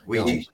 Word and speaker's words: Ouija: Ouija boards? – Ouija boards.